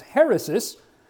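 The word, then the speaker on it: heresis (αἵρεσις): Normally I wouldn't put Greek up, but I want to put it up here to illustrate a point, and that is the pronunciation of this word heresis is the Greek term, and that little curved line right there is an H sound. That two letters in Koine and Byzantine and modern Greek is a short E sound. The rho is R, epsilon E, sigma S, iota I, and again sigma S.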